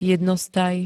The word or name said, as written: jednostaj